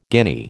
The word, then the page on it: guinea: American English